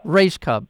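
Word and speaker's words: Rajskub: Rajcub.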